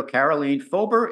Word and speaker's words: Karoleen Fober: So welcome to Guys Guys Radio, Karoleen Fober.